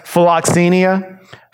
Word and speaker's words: philoxenia: It's pronounced philoxenia.